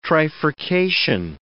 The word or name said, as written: trifurcation